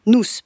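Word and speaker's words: Noûs: Nous, enfin, nous, parce que ça s'écrit N-O-U accent circonflexe S et ça se prononce nous. Comme dans Bienvenue chez nous, par exemple.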